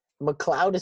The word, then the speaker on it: McLeod: Also, just want to real quick, McLeod is spelled McLeod, not like a cloud, like that's in the sky, McLeod.